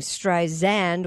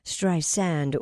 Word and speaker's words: Streisand: Barbara told NPR that Siri has been saying Streisand with a Z sound, not the correct pronunciation, which is Streisand with an S sound.